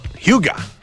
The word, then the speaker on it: hygge: ¿Tú sabías cómo pronunciarlo? – Se dice Hygge.